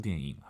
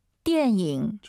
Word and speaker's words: 电影: 나부 디엔잉 하면 여기서 영화의 양사입니다. 디엔잉, 니은 발음 들리면 안 돼요. 디엔잉, 디엔잉. 다시 들어볼게요. – 电影.